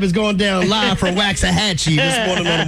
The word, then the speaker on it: Waxahachie: Waxahachie. – Ooh, Waxahachie. Waxahachie.